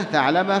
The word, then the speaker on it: ثعلبة: هذه كنيته، أبو ثعلبة كنيته واشتهر بها، وهي نطقها هكذا يعني بالثاء بعدها عين مهملة ساكنة ثعلبة فلام مفتوحة هكذا تنطق، فموحدة يعني تحتية، فأبو ثعلبة هذه كنيته واشتهر بها.